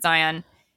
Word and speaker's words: Zain: And who the name is spelled Zane, Z-A-I-N. So, but he did pronounce it Zion.